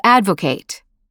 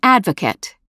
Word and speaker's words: advocate: As a verb ending, ATE is pronounced ate, to advocate. As a noun or adjective ending, ATE is reduced to et, an advocate.